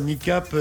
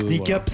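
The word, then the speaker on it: Kneecap: Non, ils font plaisir, Nicap.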